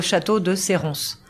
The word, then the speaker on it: Château de Serrance: Depends where you are, but usually when if you are south of the La Loire River, you pronounce the S. And specifically in our area, in southwest of France, you pronounce the S. So Château de Serrance.